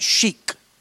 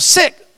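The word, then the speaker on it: Sikh: And I know we kid with this, but there's a religion, and it's spelled S-E-I-K. Some of us would mispronounce it and call it chic. Others would call it seek. It's pronounced S-I-C-K, sick.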